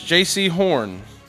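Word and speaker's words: Jaycee Horn: Buccaneers, J.C. Horn.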